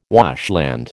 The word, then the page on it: washland: American English